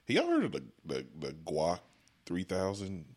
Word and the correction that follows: Gawk: I think it's spelled G A W K. – Nah, Gawk. – Gawk. Yeah, Gawk 3000.